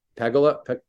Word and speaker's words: Pegula: Pegula.